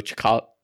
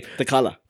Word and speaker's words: T'Challa: She's loyal to Chakot. I can't say Black Panther's name. – T'Challa.